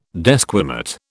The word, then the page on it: desquamate: British English